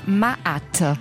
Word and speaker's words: Ma'at: Alors, si je ne me trompe pas, Ma'at.